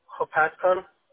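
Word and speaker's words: Hopatcong: Hopatcon. – How do you spell that one? H-O-P? – A-T-C-O-N-G.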